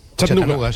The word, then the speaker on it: Chattanooga: ¿Se pronuncia así en Chattanooga? – Chattanooga, sí.